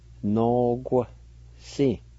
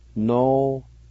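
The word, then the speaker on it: noquisi: First part is pronounced like this, and actually the U-I with a Q is silent, pronounced more like this, no-gu-si, no-gu-si.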